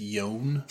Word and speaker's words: Joan: I think it's Yone.